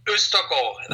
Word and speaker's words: Ostergaard: Erik Ostergaard. – Hello. – Ostergaard. No. – Ostergaard. That's Ostergaard.